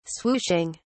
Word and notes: swooshing: British English

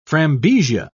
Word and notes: frambesia: British English

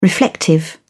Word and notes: reflective: British English

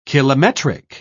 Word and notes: kilometric: British English

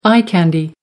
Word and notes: eye candy: American English

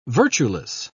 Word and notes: virtueless: British English

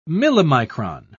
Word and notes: millimicron: British English

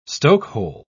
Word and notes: stokehole: British English

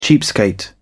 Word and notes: cheapskate: British English

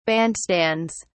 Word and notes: bandstands: British English

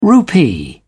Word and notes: rupee: British English

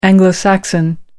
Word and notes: anglo-saxon: American English

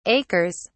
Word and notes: acres: British English

